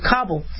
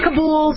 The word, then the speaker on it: Kabul: And some people say Kabul, some people say Kabul. I'm fine either way. I think both pronunciations. Kabul, I believe, is the authentic pronunciation, but Kabul, you know, it's, you could accent, I think, either vowel.